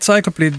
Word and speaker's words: Cycloplete: Cycloplete volgens mij. Zeg ik dat goed? – Dit is inderdaad Cycloplete, Bad Motherfucker.